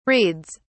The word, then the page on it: reads: British English